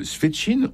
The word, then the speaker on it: Svetchine: Il y a Madame Svetchine, on prononce ça comme ça ? – Oui, je crois.